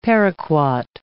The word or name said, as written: paraquat